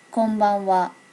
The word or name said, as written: konbanwa